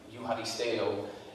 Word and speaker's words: Eucharisteo: I think it's pronounced like Euharisteo. And it's Eucharist and then E-O at the end.